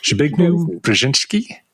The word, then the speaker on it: Zbigniew Brzeziński: He was writing at the time a biography of, I don't know if I'm going to say the name right, but Zbigniew Brzeziński. – God. – please say it. – Oh, we both can get this wrong. Zbigniew Brzeziński? – Yes.